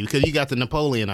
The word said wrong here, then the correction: Neapolitan: ice cream, if I'm saying it right. – Neapolitan. Close enough.